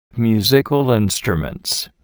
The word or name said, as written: musical instruments